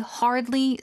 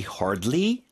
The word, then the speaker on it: hardly: 하지만 but when I ask about school, 학교에 대해서 질문을 하기만 하면은 he hardly says anything. 거의 아무 말도 하지 않는다. – 그렇죠. 그런 의미로 he hardly says anything. hardly 발음 좀 도와주세요. – Hardly. – R 발음, L 발음.